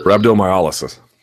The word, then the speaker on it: Rhabdomyolysis: Rhabdomyolysis.